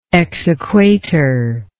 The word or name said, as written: exequatur